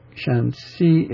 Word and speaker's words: Shanxi: Shanxi, it's the higher pitched, even pitch, the second syllable. But the shan is not even pitched.